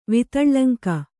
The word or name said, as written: ವಿತಳ್ಳಂಕ